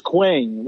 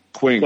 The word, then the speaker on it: Qeng: Which is Quang, which is Q-E-N-G. – Quang.